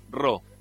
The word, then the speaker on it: Raw: suscríbanse en su canal, búsquenlo ahí, Pereda BT Rau, R-A-W. Se pronuncia Ro.